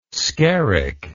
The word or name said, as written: skerrick